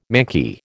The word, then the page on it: manky: American English